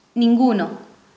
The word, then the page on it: ninguno: Spanish